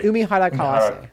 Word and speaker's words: Umihara Kawase: Umihara Kawase.